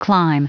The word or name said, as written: clime